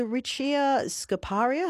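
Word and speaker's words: Richea scoparia: There's the blue bladderwort, the creamy candle, the flannel flower, the flying duck orchid, the muller muller, the Murnong, and is it the Richia scoparia? Have I pronounced that correctly? – Yes, you have. That's correct.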